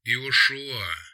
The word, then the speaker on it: Иошуа: «Иошуа. Сокращение от Иегошуа. Ударение на последний слог.